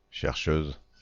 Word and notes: chercheuse: France; /ʃɛʁ.ʃøz/

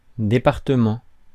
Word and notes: county: /ˈkaʊnti/

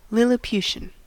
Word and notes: lilliputian: American English; /ˌlɪlɪˈpjuʃən/

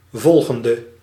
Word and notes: volgende: /ˈvɔlɣəndə/; /vɔl.ɣɛn.də/

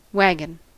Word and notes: wagon: France (Paris); IPA: [ɛ̃ va.ɡɔ̃]

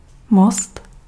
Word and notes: most: [mɔst]; /most/

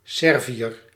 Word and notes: Serbe: /sɛʁb/

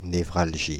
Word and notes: névralgie: [ne.vʁal.ʒi]; /ne.vʁal.ʒi/

